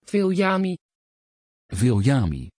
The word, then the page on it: viljami: Dutch